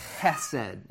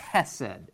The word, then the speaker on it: chesed: It's chesed. You got to say it like you're clearing your throat. It's like chesed, right?